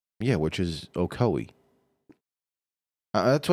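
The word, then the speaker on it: Ocoee: I think it's actually pronounced Ocowee. – Oh, I'm not going to try and butcher that name. – It's O-C-O-E-E. So, whatever.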